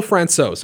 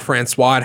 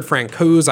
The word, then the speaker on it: Francouz: It's a Czech name that looks like Francois, but it's not Francois. It has a Z at the end. It looks really weird. I've heard Francouz. I've heard Francouz.